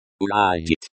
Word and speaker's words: уладить: Уладить. Pronounced уладить, like уладить, with the emphasis on the last syllable, means to settle or to resolve.